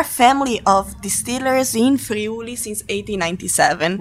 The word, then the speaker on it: Friuli: You are from a specific section of Italy, Friuli. See, am I saying it correctly? – Yes.